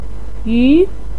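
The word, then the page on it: yu: tone 2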